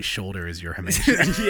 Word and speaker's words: hamartia: Hamartia. – Hamartia. hamartia. – Is that confirmed or how you're interpreting? – That's how Google told me to pronounce it.